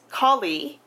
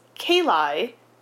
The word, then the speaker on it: Kali: So the priest of that temple invokes Kali, but who they consistently mispronounce as Kali, Kali is a Hindu goddess.